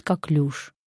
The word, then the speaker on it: коклюш: Правильный ответ – «коклюш». Конечно, лучше быть здоровым и не сталкиваться с употреблением этого слова. Но если придется, говорим правильно, как «люш», и замечаем, что ударение падает на последний слог.